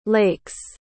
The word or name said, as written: lakes